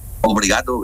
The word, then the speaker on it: Obrigado: ¿Cómo, obligado, sí, ¿cómo se dice? Obligado es gracia, ¿no? – Obrigado, gracias.